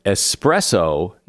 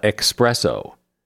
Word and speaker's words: espresso: Strong coffee in a tiny cup has no X in it, either in writing or pronunciation. It is espresso, not expresso.